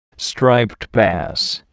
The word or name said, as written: striped bass